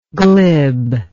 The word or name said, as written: glibness